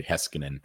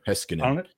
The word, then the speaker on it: Heiskanen: Yeah, that's not how you actually pronounce the name. I just like to dramatize it. It's really Heskinen, I think, is the proper Finnish way. Heskinen.